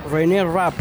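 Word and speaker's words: Renée Rapp: René Rapp, ¿lo estoy pronunciando bien? – Es como René de Calle 13, pero se escribe distinto.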